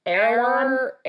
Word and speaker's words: Erewhon: Well, there is one that I went to that was in Venice, which is called Erewhon. – That's the one. Okay. That is the one. – How do you pronounce it? – Erewhon.